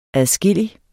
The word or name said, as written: adskillig